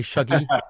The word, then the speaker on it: Shuggie: I'm not going to try and do a Glaswegian accent, but if I say Shuggie, is that okay? – That's pretty good. – Okay. I'm just going to say Shuggie, but with my sort of flat London brogue or whatever it is that I've got.